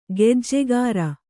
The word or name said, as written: ಖಜ್ಜಾಖೋರ